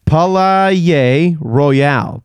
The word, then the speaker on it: Palaye Royale: I hope I'm pronouncing this right. They're called Palaye, P-A-L-A-Y-E, Palaye Royale.